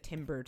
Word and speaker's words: timbre: But I would say his voice itself has like a very like nice like it's got a nice like timbre to it.